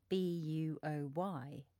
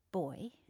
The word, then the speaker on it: buoy: Unlike as we discovered, some of our American colleagues and how they pronounce the word B U O Y. So for me, then that's boy. – Yeah. Yes. Obviously. – North America it is, wait for it, buoy.